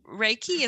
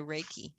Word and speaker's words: Reiki: If I'm saying it correctly, Reiki? – Reiki is what I call it. Reiki, okay. Like R-A-Y-K-E-Y, Reiki.